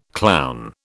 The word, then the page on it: clown: British English